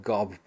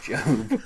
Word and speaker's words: GOB: She's the first person to say Gob Bluth instead of Job.